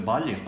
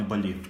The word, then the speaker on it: Балі: Е, іменно правильно говорити балі, а не балі.